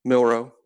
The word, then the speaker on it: Milroe: But the offense you put down, at quarterback you have Jalen Milroe from – is it Milroe? Is that how you say it? – Milroe, yeah, Milroe.